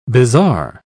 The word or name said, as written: bizarre